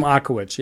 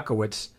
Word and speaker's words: Ochowicz: And the other name we mentioned, Jim Oknovitz. How do you say his name? Jim Ochowicz. – Yeah, Ochowicz. For